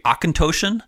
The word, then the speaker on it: Auchentoshan: From the southern portion of Scotland, and it is from the distillery Auchentoshan. And I looked up how to pronounce that, and I got it right. And I had it right.